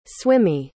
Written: swimmy

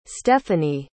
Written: stefanie

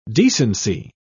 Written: decency

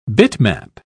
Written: bitmap

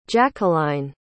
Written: jackeline

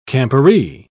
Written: camporee

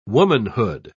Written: womanhood

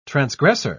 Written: transgressor

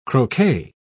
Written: croquet

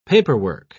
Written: paperwork

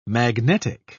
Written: magnetic

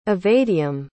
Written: evadeam

